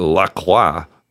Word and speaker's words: LaCroix: Oh, Lacroix. That's the way you pronounce that soda, apparently.